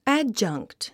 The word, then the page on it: adjunct: ǽdʒʌŋkt; アッジョンクト